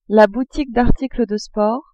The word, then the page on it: boutique d'articles de sports: female